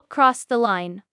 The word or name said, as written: Cross the line